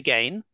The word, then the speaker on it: Gaine: Dr. Gaine, that's DRGAINE, on Instagram, X, Letterboxd, Threads, and now, thanks to the lovely James, also Blue Sky.